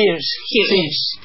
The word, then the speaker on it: Hirsch: Una peli que habla sobre Narcisa Hirsch, ¿cómo se dice? – Hirsch.